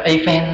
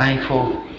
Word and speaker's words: Eiffel: Giống như ông Einstein, ông Eiffel, mình hay gọi là Eiffel đó. Chúng ta nhớ bên tháp ở Paris có cái tháp nổi tiếng nhất là tháp tháp Eiffel, tiếng Anh đọc là Eiffel.